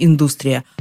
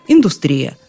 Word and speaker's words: индустрия: Получается, сначала в русском языке взяли за основу латинское ударение «индустрия», а уж потом, ближе к концу XX века, французская «индустрия».